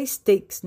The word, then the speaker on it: Stacks: Ele se escreve assim, ó, Blue de azul, Stacks. Mas na verdade se pronuncia Stakes, né?